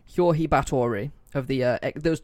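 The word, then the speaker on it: György: Her father was a baron, and it says George, but apparently it's pronounced like Jorge or Hjorhi, I think.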